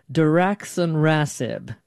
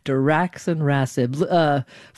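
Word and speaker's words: daraxonrasib: Diraxonrasib. Did I say that correctly, doctor? – Yes, well done. – Okay, Diraxonrasib.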